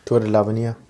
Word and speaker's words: Tour de l'Avenir: Tour de l'Avenir.